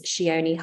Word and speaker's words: Chione: And if you'd like to keep up with my work, my Instagram is Shione Hardy, which is spelled C-H-I-O-N-E-H-A-R-D-Y.